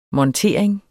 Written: montering

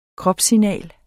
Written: KROPSSIGNAL

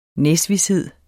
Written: næsvished

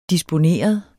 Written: disponeret

